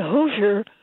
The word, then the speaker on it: Hoosier: Okay, well, in St. Louis, when we say Hoosier, that refers to a person from Indiana. But when we want to use it as a derogatory term, it's pronounced Hoosier, like H-U-S-H-E-R, Hoosier.